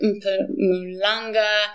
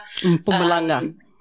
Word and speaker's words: Mpumalanga: And they are having the most spectacular time up at Mpumalanga. – Mpumalanga.